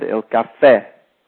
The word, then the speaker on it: caffè: Notice the accent on the end, il caffè.